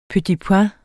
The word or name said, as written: petit